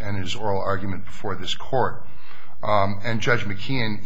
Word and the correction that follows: McKeown: easily dispatched and distinguished. – McKeown. – I'm sorry, McKeown.